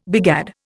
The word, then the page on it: begad: Indian